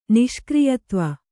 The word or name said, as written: ನಿಷ್ಕ್ರಿಯತ್ವ